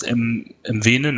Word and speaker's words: Mwene: gefallen durch Gauss, Vorarbeit, MW, wie spricht man aus? MWene, ne, glaube ich? – Ja, genau.